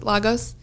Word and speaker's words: Lagos: Lagos? – Yeah, uh-huh.